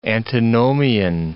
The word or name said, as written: antinomian